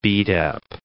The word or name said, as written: beat-up